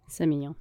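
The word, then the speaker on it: Sémillon: Is that how you say that? – Semillon.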